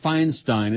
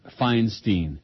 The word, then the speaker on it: Feinstein: Oh, and by the way, Dianne Feinstein, it's not Feinstein. Who has been lately saying these news readers on TV, on CNN, Feinstein? I don't know anybody who pronounces that name, Feinstein.